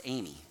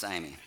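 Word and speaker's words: agape: Some of you probably hope it was Amy, but it's actually pronounced aimi, especially if you're Amy here.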